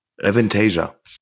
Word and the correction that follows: Avantasia: Habe ich das jetzt richtig gesagt? – Ja, Damit klar. würde sagen Avantasia. So. – Ja, das, ist richtig.